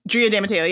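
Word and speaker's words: Drea de Matteo: Is it Drea DiMatteo? Is that how you say her name? – Drea DiMatteo. Yeah.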